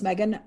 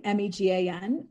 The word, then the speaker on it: Megan: So my Twitter handle is Megan underscore McNealy, M E G A N. And then my last name is spelled M C N E A L Y. Instagram, it's at Megan McNealy.